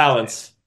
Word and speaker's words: Valance: How do you pronounce his name? – Valence.